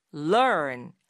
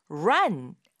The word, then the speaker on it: learn: run, 뛰다라고 할땐 run, run 이렇게 말하지만 좀 짧고 그리고 r 발음이 혀 끝을 살짝 올려서 당기면서 내는 발음이잖아요. run. 근데 그에 비해서 배우다라는 뜻의 이 동사 learn 같은 경우는 일단 시작점 자체가 다르거든요. 혀 끝이 윗니 바로 뒤에서 콕 점을 찍듯이 찍었다가 떼면서 발음하게 돼요. learn 이렇게요.